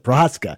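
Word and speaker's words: Procházka: Yuri Prochazka, I swear I know how to pronounce that, Prochazka, looking pretty good.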